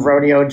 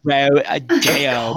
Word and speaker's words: Rodeo: Rodeo Drive, and I still laughed my ass off. – She's so stupid. – Say it right. – Rodeo.